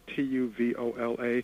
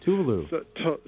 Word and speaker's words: Tuvalu: But anything that's another thing that's interesting, the country of, I think, I'm not sure if I'm pronouncing it right, but it's, I think it's Tuvola. – Tuvalu.